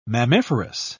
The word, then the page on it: mammiferous: British English